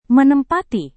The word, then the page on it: menempati: Indonesian